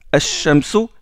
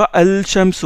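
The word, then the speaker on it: الشمس: Seulement, le lam, vous ne le prononcez pas, vous ne dites pas al-shamsu, vous dites ash-shamsu direct.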